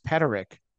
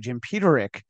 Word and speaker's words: Peterik: And that's how I know that your name is not pronounced Peterik, because I heard Dennis DeYoung call you Jim Peterik.